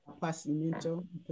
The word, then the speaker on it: appassimento: How do you say that? Appetismento? – I don't know what you're saying. – Can you see it? – Appassimento. – Is that French or Italian? – It's Italian.